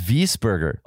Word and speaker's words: Wiesberger: It's Wiesberger. – Oh, that's how you say it. – Wiesberger. – What? Austrian. That's how you say it in Austrian.